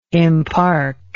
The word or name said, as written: impark